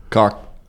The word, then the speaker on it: caulk: Cock contractor. – How do you spell that? – C-A-U-L-K. – C-A-U-L-K. – Cock. But besides the point, that's neither here nor there.